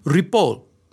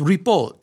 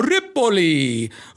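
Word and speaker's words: Ripoll: It's really Rapole. It's Rapole. And many people think that it's Italian. Oh, Ripoli, Ripolini, Ripoli. And there's no vowel at the end. There's no I.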